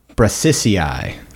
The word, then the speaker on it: Brassicae: And I looked it up, and it's pronounced Brassicae. – Brassicae.